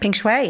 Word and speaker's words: Peng Shuai: Is it Peng Shuai? – Peng Shuai.